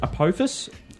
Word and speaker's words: Apophis: I think you pronounce it Apophis.